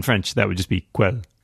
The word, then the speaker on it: Quelle: Q-U-E. LLE, which you, since you speak German, might that's Quelle.